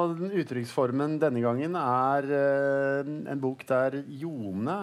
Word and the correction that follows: Ilone: er Det er sånn det uttales? Ilone. Ilone? – Ja.